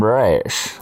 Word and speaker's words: Ra's: Am I pronouncing that correctly? Ra's? – I call him Ra's.